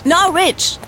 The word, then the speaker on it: Norwich: Norwich. Es wird Norwich ausgesprochen. – Oh. Norwich? Ah, okay. Das wusste ich gar nicht. – Ich auch nicht. – Ja, das geht vielen so. Weil es ja auch Norwich geschrieben wird. Die Aussprache ist dann eher überraschend.